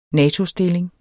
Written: natostilling

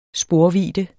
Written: sporvidde

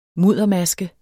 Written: muddermaske

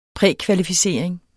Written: prækvalificering

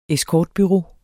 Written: escortbureau

